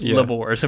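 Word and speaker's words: Lugbúrz: So there's a lot of talk about this Labor's person who, I'm only pronouncing it that way because this is in the audiobook, which I listened to for part of this, but it's spelled Lugburz, but that's Labor's apparently.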